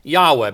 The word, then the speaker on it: Yahweh: Thinking about O Jehovah, our Master, Jehovah, more probably pronounced Yahweh. Because of this very confusion with all of this, there is no absolute standard way of understanding how to pronounce it.